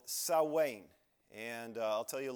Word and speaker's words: Samhain: We go back to somebody named Samhain, and it's spelled, it looks like Samhain, maybe on your paper. It looks like that. It's pronounced Samhain. So many,